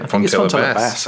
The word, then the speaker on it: Fontella Bass: Fontella Bass, actually, yeah.